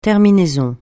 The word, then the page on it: terminaison: francais